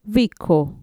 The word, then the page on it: viko: Mixtec